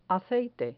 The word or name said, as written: aceite